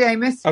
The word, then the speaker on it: Amess: David Amos. Am I saying that right, or is Amos? – Yeah, David Amos, okay.